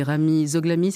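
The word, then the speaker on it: Ramy Zoghlami: Yuma, c'est Sabrine Jenani qui était au chant et Rami Zoglami. C'est comme ça qu'on prononce ? – Yes. – Yes.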